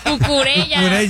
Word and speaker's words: Cucurella: No, pero se pronuncia cucuyella. Ya no puedo. Cucurella.